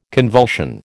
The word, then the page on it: convulsion: American English